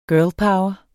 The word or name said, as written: power